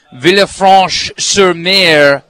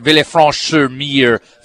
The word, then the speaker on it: Villefranche-sur-Mer: In this particular case, it was Lily Safra's Villa Leopolda in Villefranche-sur-Mer, France. I think I pronounced that pretty close. – Oh, that was perfect. – That was my first crack ever at Villefranche-sur-Mer, France.